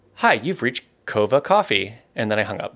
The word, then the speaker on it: Coava: Coffee by a company called Coava.